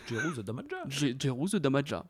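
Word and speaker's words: Jeru the Damaja: préfère écouter Sea Murder par rapport à Jerus de Jamaja. Je suis d'accord. – Déjà, est-ce que je le prononce bien cet artiste oui, Jeru Damaja. voilà.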